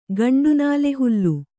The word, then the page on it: ಗಂಡುನಾಲೆಹುಲ್ಲು: gaṇḍu nāle hullu